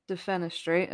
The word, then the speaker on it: Defenestrate: So I've never said this before, but I have to say your book, DEFENESTRATE, am I saying that right? – Yeah, you nailed it.